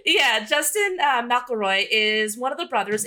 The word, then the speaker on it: McElroy: Oh, actually, I think I pronounced McElroy. That's how they like to pronounce it.